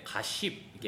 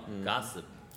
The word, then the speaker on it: gossip: 예, 그리고 아까 말씀하셨을 때 gas 이렇게 발음하셨는데 한국 사람들이 이걸 발음할 때 가십 이게 sh 발음으로 가십 하시는 경우가 있는데 안 돼요. 가습. 가습. 우리가 가습기 있잖아요. 사실 그걸 생각하시고 가습. – 맞아요. 그거랑 거의 똑같아요. 그냥 가습.